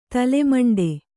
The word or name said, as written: ತಲೆಮಂಡೆ